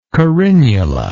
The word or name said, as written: carinulate